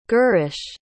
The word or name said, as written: girish